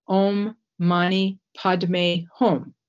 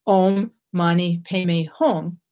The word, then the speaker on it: Om Mani Padme Hum: He said, teaching the mantra, Om Mani Padme Hum, which is the way it's pronounced in Sanskrit, or Om Mani Padme Hum, which is the way that it's pronounced in Tibetan.